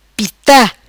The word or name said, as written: Pita